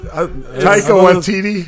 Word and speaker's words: Taika Waititi: Isn't that what Taika Waititi? – Taika, yeah, that was close.